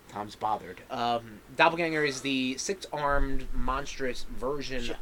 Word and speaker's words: Doppelganger: Next up is Doppelganger. – That's how it's pronounced. – That's just spelled. Weird. – That's your language, guy. – Not my language. – Yeah. No. Germanic. Germanic in origin. – Yeah.